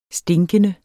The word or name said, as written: stinkende